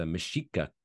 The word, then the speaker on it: Mexica: And the tour guide was the original Mexican people was the Mexica tribe. So technically, OG Mexico is pronounced Mexica. Mexico. – Interesting. Okay. – Rather than Mexico.